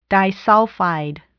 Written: disulfide

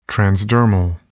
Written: transdermal

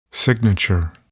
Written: signature

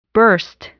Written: burst

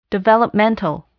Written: developmental

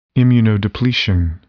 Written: immunodepletion

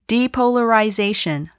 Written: depolarization

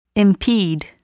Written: impede

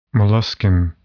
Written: molluscan

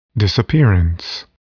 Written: disappearance

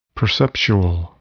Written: perceptual

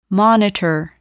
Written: monitor

